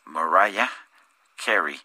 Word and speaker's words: Mariah Carey: Es Mariah Carey, se escribe eh, María con H al final y Carey se, escribe C-A-R. E-Y. Eh, si, si españolizáramos el nombre sería María Carey pero en inglés, eh, que es el idioma eh, de ella, se pronuncia Mariah Carey y eh, el apellido se, se escribe Carey.